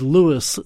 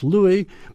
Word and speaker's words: Louis: And I emphasize the Louis because in that song where he sings Hello, Dolly, he then goes on to say, this is Louis Dolly.